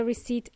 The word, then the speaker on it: receipt: When you pay for something, you get a receipt at the end. Okay? Receipt. There is a silent P in there.